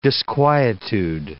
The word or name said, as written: disquietude